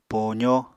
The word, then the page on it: poozó: Apatani